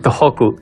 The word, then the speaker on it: Tohoku: ¿Cómo es Tohoku o Tohoku? Tohoku. Con H, aspirada. Tohoku, o sea que estás lejísimos de nosotros.